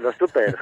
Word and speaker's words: Los Tupper: Y lo digo bien, Los Tupper, ¿verdad? – Así es, Los Tupper.